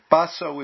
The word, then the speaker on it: Baso: Baso is the Chinese way of, no, Baso is the Japanese way of pronouncing the characters, which in Chinese are Matsu, which literally means ancestor Ma or ancestor Ba. With Baso, Ma or Ba means horse.